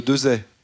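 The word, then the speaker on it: Desaix: Farget avec un X à la fin qu'on ne prononce pas. – C'est bien ça. – C'est comme le général Dezay.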